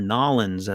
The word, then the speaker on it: New Orleans: Let's go down to New Orleans, or Nolens, as it's pronounced down there.